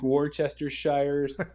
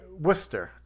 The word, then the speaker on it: Worcestershire: So the actual pronunciation is Worcestershire. – Worcestershire? – Worcester. W-O-O-S-T-E-R. Worcestershire sauce. – Worcestershire sauce. – You put too many syllables in there. It's not Worcestershire, it's just Worcestershire.